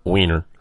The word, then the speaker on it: Weiner: But, you know, this is a cultural thing now in our country in a big way because of Anthony Weiner. Did I say that right? Weiner.